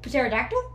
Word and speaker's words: pterodactyl: Pterodactyl? That doesn't make sense. – No, it's a silent P.